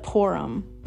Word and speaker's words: Purim: And it goes on to say that it was called, and it's pronounced three different ways, but Purim is how I'm going to say it.